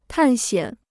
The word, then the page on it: 探险: tàn xiǎn